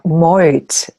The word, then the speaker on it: Meuth: Yeah, Meuth. It's spelled like, phonetically spelled, M-O-I-T.